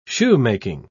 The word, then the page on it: shoemaking: British English